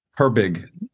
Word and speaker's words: Herbig: And they have some, Nate Herbert can play center. – Yeah. – Did I say that right? – Herbig.